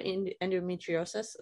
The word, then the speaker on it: endometriosis: And the one guy came in and he was like, you might have endometriosis.